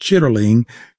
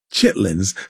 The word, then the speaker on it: chitterling: It was part of what they called the, even though the word is more accurately pronounced, chitterling, we say Chitlins.